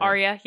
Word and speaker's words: Arya: Maisie Williams, who is mostly known for her role as Arya Stark in Game of Thrones. – Yay. – I said that correctly. – Right? Arya, yeah.